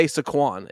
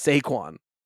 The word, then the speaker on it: Saquon: You still say Saquon. It's Saquon.